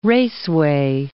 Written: raceway

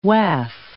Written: Waf

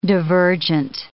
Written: divergent

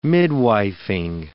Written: midwifing

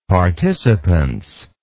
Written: participance

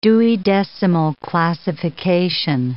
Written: Dewey decimal classification

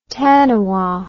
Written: taniwha

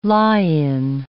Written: lie-in

